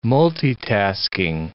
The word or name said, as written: multitasking